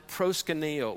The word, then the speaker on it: proskuneo: The words fell down before him in the Greek come from a word that is pronounced proskuneo, which means to worship.